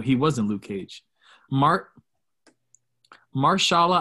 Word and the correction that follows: Mahershala: Ali will play Blade in the MCU. Is that how you say his name? Mahershala Ali. Mahershala Ali.